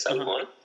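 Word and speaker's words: salmon: salmón. – Claro. – Pero en inglés la L no se pronuncia.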